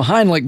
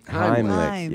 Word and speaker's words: Heimlich: Well, just a few days ago, we had National Heimlich Maneuver Day. – I don't think you're saying that right. – Really? Let me take a look here. Henry Heimlich. – Heimlich, yes.